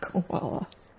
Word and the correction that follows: Kuala Lumpur: Okay. – Koala is an animal in Australia. – What is it? How do you say it? – Kuala Lumpur.